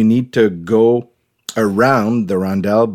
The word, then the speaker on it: rondel: There's sort of like a rondel. Like, is that how you pronounce it? – Rondel.